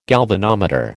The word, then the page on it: galvanometer: American English